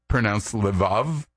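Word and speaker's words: levav (לבב): In Hebrew, the word heart is sometimes pronounced levav, or more often, It's in a shorter form called Lev.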